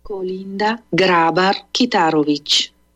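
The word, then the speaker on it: Kolinda Grabar-Kitarović: Kolinda Grabar Kitarovic. – I'll play it one more time. – Kolinda Grabar Kitarovic.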